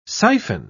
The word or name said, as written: siphon